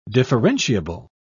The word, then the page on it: differentiable: British English